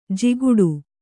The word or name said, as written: ಜಿಗುಟು